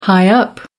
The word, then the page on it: high up: American English